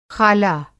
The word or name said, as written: ख़ाला